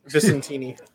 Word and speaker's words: Vicentini: All right, so ASM 70, it's written by Nick Spencer with art by Federico Vincentetti. I, I say that wrong every time. I'm sorry. – Vicentini. But how's the anchor? Vicentini.